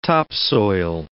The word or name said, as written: topsoil